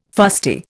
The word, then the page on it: fusty: Indian